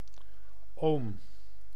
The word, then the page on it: setä: /ˈse.tæ/